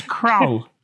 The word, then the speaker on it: Krull: K-R-U-L-L. – Oh, yeah, that's very similar to Krill, isn't it? – Yeah.